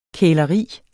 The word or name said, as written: kæleri